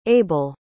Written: abel

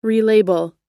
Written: relabel

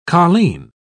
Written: colleen

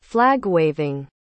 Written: flag-waving

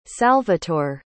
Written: salvatore

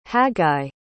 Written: haggai

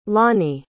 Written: lonny

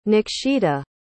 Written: nakshita

